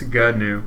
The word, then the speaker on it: GNU: two syllables, GNU. – Oh, it is GNU. – That's what Wikipedia's pronunciation key says.